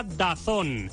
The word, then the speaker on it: DAZN: DAZN se pronuncia DAZN.